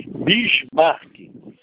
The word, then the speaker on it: Bismarck: Como que pronuncia seu nome, homem? – Bismarck. – Ah, tá, Bismarck.